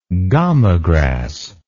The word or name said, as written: gamagrass